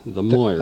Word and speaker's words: Moirs: These are the, I don't know how to pronounce that. The Moors? – Yeah, the M-O-I-R-S. The Moors.